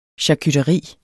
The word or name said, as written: charcuteri